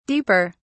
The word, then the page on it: deeper: British English